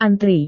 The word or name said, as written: antre